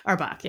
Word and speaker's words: Auerbach: Whole side of my family, it's the Auerbach. – Okay. So for you though, it's Auerbach. – Auerbach, yeah.